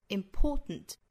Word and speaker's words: important: 那这个实词它的这个重读音节呢是在第二个音节，所以读成 important important。